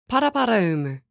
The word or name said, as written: Paraparaumu